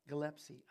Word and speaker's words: Gillespie: Gillespie. I said it right, but I spelled it wrong.